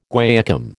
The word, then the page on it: guaiacum: American English